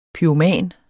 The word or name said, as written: Pyroman